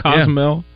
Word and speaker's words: Cozumel: Cozumel.